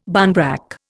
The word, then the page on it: barnbrack: Indian